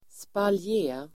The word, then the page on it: spaljé: [spalj'e:]